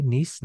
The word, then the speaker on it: Nyce: It's called "A Single Website Has a Chokehold on Surfing," an article by Caroline Mims Nice. I believe I'm saying that correctly. Nice, Nice, NYCE.